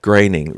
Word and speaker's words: Groening: and he would occasionally put in Groening rhymes with complaining, not moaning.